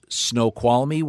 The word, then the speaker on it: Snoqualmie: Is it pronounced Snoqualmie, Washington? – You got it.